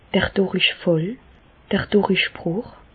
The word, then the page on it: d'r Dùrichfàll, d'r Dùrichbrùch: Alsatian; Bas-Rhin; Reichshoffen